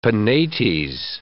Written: Penates

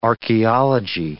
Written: archeology

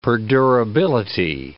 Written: perdurability

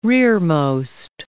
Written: rearmost

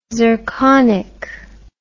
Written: zirconic

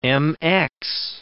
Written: MX

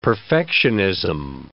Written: perfectionism

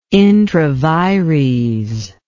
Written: intravires

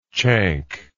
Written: chank

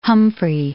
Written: Humphrey

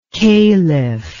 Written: kaliph

